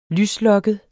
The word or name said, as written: lyslokket